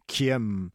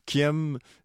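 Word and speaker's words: Khiêm: Qui aime, ça s'écrit K. H-I-E, accent circonflexe, avec le petit chapeau, quoi? M, Kiem. Kiem, terre maternelle. C'est le nom de la mère de Djibril et de Yasmine, Fan, P-H-A-N, Morissette.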